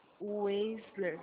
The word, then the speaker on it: Weisler: Weisler, é isso? – Isso. – Eu falei certo? – Falou. Você vai escrever certo, não falo certo.